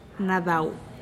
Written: nadau

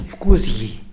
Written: pkuzgi

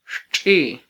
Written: xtxi

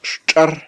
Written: xtxar

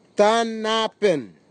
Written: tanapin

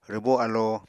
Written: rwbó alo

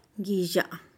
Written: giizha'a

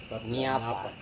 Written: nyapa

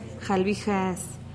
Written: jalbijees

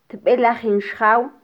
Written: tbelajin xjaw

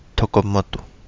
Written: TogoModu

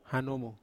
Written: hanomu

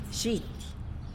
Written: zhity

